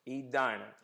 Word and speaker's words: Edina: County was named after Henry Knox, and the town, I can pronounce it, but Commissioner, tell me how you'd pronounce it to somebody from Springfield. The town right here. – Edina. – I've heard Edina, and I knew it was Edina, but I didn't trust myself enough to say it first.